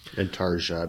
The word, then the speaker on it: intarsia: Intarsia, I believe it's pronounced.